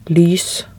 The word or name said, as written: lys